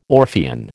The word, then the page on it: Orphean: American English